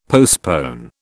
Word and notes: postpone: British English

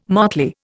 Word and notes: motley: Indian

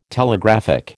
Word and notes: Telegraphic: American English

